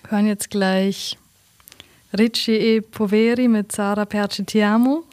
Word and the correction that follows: Perché Ti Amo: Habe ich das richtig ausgesprochen? – Perché Ti Amo? – Oh.